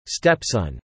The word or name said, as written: stepson